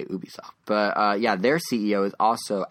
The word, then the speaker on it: Ubisoft: Ubisoft, or Ubisoft. I recently learned both pronunciations are correct.